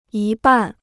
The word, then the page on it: 一半: yī bàn